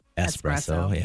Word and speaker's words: espresso: Do you want an extra shot of espresso? No, I don't. – Oh, that was right. Espresso.